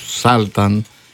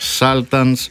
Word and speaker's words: Sultans: Οι Άγγλοι όμως άλλαξαν την προφορά της όταν την ενέταξαν στη δική τους γλώσσα. – Μάλιστα. – Επειδή οι Dire Straits είναι βρετανικό συγκρότημα και τραγουδούν στα αγγλικά, προφέρουν τον τίτλο ως Sultans of Swing.